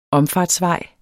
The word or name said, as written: omfartsvej